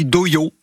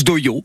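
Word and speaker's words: dohyō: Alors oui, on dit Dōyō, on prononce Dōyō. On dirait un peu un gars qui dit Dojo, mais qui a rendez-vous chez l'orthophoniste, mais c'est bien Dōyō.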